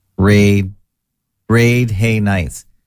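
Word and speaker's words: Radhanites: Well, I looked it up and they said it's both A's are long. So if both A's are long, you're supposed to pronounce it Raid Hay Nights.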